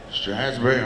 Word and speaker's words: Strasburg: Okay, good morning there, Strasburg. Did I say that right? Strasburg, oh, yeah.